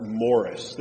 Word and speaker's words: Maurice: There was a famous pastor in Scotland, and his name was spelled M-A-U-R-I-C-E. And growing up, I would call him Maurice and not Morris.